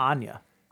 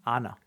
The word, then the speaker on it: Anya: Not Anya. Anna. – No, it's Anya. – No, it's not. It's Anna Taylor-Joy. – It's spelled A-N-Y-A. – The Y is silent.